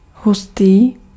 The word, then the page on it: hustý: /ˈɦus.tiː/